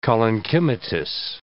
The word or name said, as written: collenchymatous